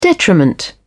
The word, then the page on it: detriment: British English